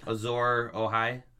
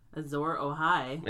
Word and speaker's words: Azor Ahai: Who is Azor Most High? Oh, hi. – Who is what? – Azor Ahai. – Azor Ahai. – Yeah. I think that's how you pronounce it correctly.